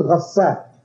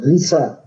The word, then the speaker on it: ghasaq: That's why we say the pus of the people in hellfire is called ghasaq, sometimes pronounced ghisaq.